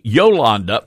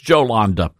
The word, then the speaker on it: Jolanda: Her name is it Jolanda or Jolanda Jones? Or it could be Yolanda, but I don't know if the J is silent. Yolanda Jones. No, that doesn't work. It's Jolanda.